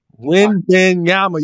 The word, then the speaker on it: Wembanyama: Wembanyama, I hope I said his name right. Wembanyama. – Wembanyama. You better not mess that up.